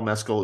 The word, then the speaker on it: Mescal: Don't sleep on Frankie Corio, because, again, the only reason why Paul Mescal is, and that is the correct pronunciation of his name, as we all read today, the only reason why Paul Mescal is so great in that film is he has her to play off of.